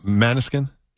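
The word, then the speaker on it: Måneskin: You know Maneskin? – Yeah.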